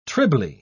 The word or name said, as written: trilby